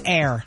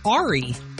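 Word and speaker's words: Aire: It's A-I-R-E. So at first I thought it was Ari. Like, oh. You know what I mean? Mm-hmm. But it's Air.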